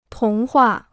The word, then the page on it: 童话: tóng huà